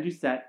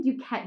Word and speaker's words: Doucette: Yes, Ben Doucette. – I always want to say Duquette, but it's because I watched Grey's Anatomy. – Right. Like, it's spelled like the same. No, it's Ben Doucette, our lovely Will's boss.